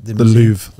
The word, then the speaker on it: Louvre: is that how you say that? – The Louvre. – The Louvre. Oh, it's just the Louvre. Yeah. No, no E on the end. The Louvre. – It's spelled that way, but it is. It's the Louvre.